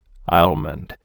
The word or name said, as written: almond